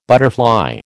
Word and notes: butterfly: American English

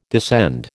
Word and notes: descend: American English

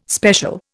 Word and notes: special: Indian

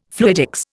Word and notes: fluidics: Indian

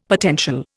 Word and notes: potential: Indian

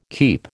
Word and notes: keep: American English

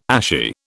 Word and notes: ashy: British English

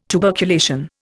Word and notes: tuberculation: Indian

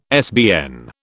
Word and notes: SBN: British English

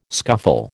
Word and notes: scuffle: American English

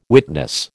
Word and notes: witness: American English

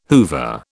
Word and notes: Hoover: British English

